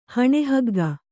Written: ಹಣೆಹಗ್ಗ

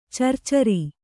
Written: ಚರ್ಚರಿ

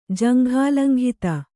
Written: ಜಂಘಾಲಂಘಿತ

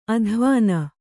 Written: ಅದ್ವಾನ

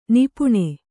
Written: ನಿಪಾನ

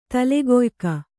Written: ತಲೆಗೊಯಿಕ

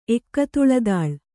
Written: ಎಕ್ಕತುಳದಾಳ್

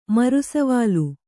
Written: ಮರುಸವಾಲು

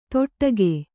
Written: ತೊಟ್ಟಗೆ

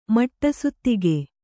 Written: ಮಟ್ಟಸುತ್ತಿಗೆ

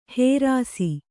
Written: ಹೇರಾಸಿ

